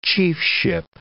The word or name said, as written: chiefship